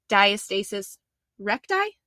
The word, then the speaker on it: diastasis recti: So I know there's a lot of issues that people have talked about, and people sent in questions about, am I going to say this right? Diastasis recti? Is that right? – Yeah, you got it.